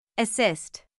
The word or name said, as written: assist